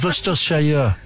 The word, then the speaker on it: Worcestershire: Mais ça se prononce Worcestershire.